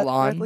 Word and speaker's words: Solon: Solon. I pronounce that Solon. – Correctly? Yeah.